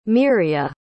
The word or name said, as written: miriah